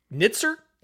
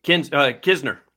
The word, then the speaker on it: Kisner: The Andrew, I can never say his name. Nitzer? Kinzer? – Kisner. – Kisner. – Yep.